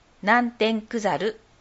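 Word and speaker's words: 南天九猿: 南天クザルを作ろうの講座を紹介します。南天クザルは、東西南北の南、天気の天、漢数字の九、そして動物の猿と書いて、南天クザルと読みます。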